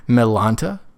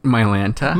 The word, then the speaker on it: Mylanta: Where he was talking about a medication, and he pronounced it Melanta. – Mylanta? – Mylanta, but he fully Melanta. And then I thought. Have I been saying it wrong this whole time? – No. No, because all my... Oh, my Lanta from Full House. – Yes, exactly. Yeah. But he said Melanta. – No, that's wrong.